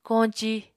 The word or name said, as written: koñci